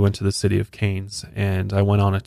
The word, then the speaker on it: Cairns: I guess it's not huge by any means, but a city called Cairns. I believe I'm saying that right. It's spelled C-A-I-R-N-S, but in Australia they pronounce it as Cairns, which is kind of interesting.